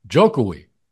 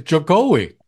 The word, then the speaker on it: Jokowi: So I always thought that his cool portmanteau nickname was pronounced Jokowi. – Oh, but what is it? Jokowi? – Actually, it's Jokowi.